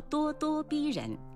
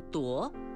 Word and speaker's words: 咄: 多多也是叹词，表示感叹或惊诧，形容气势汹汹、盛气凌人，叫咄咄逼人；使人非常惊讶的事，叫咄咄怪事。多只有一种读音，请不要读成夺，说咄咄逼人、咄咄怪事是不正确的。